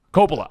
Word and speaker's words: Coppola: How do you pronounce it? Coppola. I did it right.